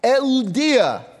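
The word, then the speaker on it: Euodia: He says, I entreat Eldia. By the way, that's how you say that. I looked it up in the Greek and I practiced.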